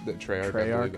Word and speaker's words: Treyarch: I think Treyarch, that's how I pronounce it. – Treyarch, that's what it is.